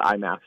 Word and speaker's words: IMMAF: We just call it IMAF, so the International Mixed Martial Arts Federation.